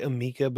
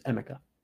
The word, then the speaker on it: Emeka: I always want to say Amika, but I feel like that's not right. – Yeah, that's – It's the wrong way. It's Emeka.